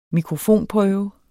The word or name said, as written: mikrofonprøve